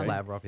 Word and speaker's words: Lavrov: Is that how you say it? – Uh-huh. – Lavrov, yeah.